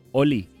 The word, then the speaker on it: Holi: ¿Ustedes conocen el festival Holi? o Oli. Se escribe literal H-O-L-I. – Holy, santo. – Oli. – Sí, es, en realidad, porque es un festival hindú.